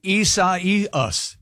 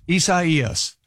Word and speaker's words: Isaias: It's Hurricane Isaias. Isaias.